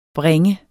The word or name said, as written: bringe